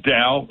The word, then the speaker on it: Daou: One of them is called Dow, like D-O-W, but it's spelled D-A-O-U.